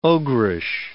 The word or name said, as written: ogreish